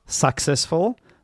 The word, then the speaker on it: successful: Successful. Not successful, but successful. Yeah, the first syllable has the schwa sound, that's why it's not stressed.